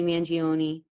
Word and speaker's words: Mangione: How do you pronounce that? – Can I see the name? I forgot what his name is. – Luigi. Hold on. Mangione. Sorry. – Mangione.